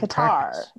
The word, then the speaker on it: Qatar: I typed in Qatar pronunciation and it says K-U-H with a space T-A-A-R. And if I click Qatar. – Qatar.